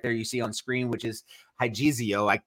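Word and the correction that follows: hagiazo (ἁγιάζω): Did I pronounce that properly, JD? Hagizio? Hagiazo? – Yeah, hagiazo. Yeah.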